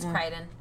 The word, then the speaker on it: Crichton: It might be Crichton. – Crichton. – It's Crichton. – I was like, I don't know how to pronounce it.